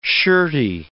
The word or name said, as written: shirty